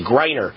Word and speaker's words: Griner: I'm going to pick up Brittany, how do you pronounce her name again? Griner, I think it is actually. Griner.